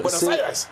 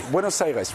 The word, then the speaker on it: Buenos Aires: Voici, là, on est à Buenos Aires, c'est sur ces images. – Buenos Aires! – Le Buenos Aires, oui, en effet. L'accent tonique est important.